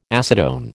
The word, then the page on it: acetone: American English